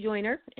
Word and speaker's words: Joyner: On Facebook, I am Mary Joyner, and my last name, Mary is spelled regular, M-A-R-Y, Joyner is spelled J-O-Y-N-E-R.